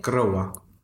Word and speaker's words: Groa: So one of them is called Groa. Is that how you say it? – Groa.